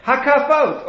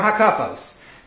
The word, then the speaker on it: Hakafot: Hakafot or hakafos, depending on how you say it.